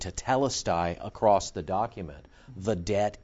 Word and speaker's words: tetelestai: Tetelestai. Well, yeah, tetelestai. The debt is paid. Yeah, you just said it right there.